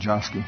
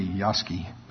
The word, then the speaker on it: Yosky: How many of you are old enough or been around here long enough to remember Rocky and Josky? I always say Josky. I know it's really Yosky, but it's spelled Josky.